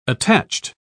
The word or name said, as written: attached